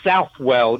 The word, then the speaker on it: Southwell: Yeah, actually, it's spelled Southwell. You know the English. We always pronounce our words incorrectly. So it's spelled Southwell. Saint Robert Southwell.